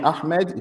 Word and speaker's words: Ahmed: So you can just call me Ahmed.